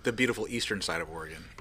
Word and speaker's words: Oregon: We've been in all kinds of different states, from Oklahoma to New Mexico to Wisconsin, Indiana, Illinois, and now Oregon. I don't say Oregon. You didn't say Oregon. You said it right that time.